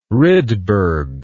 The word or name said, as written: rydberg